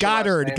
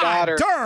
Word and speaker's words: Goddard: again, Mr. Gary, because I still can't remember how to pronounce Goddard. Goddard, as in God. Goddard.